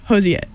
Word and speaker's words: Hozier: Hozier. – That is how my Athens, Ohio family pronounces it. Hozier.